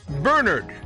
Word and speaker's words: Bernard: The first syllable rhymes with fur or sir, burr. Second syllable rhymes with bird or word, nerd. So Bernard. The emphasis stress in the place in the second syllable, Bernard, is confusing all of us, isn't it? – Today's saint is Saint Bernard of Clairvaux.